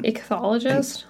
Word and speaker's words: ichthyologist: And he, how do you pronounce this? It's the, you research fish. – Ichthyologist? – Ichthyologist? Anyway.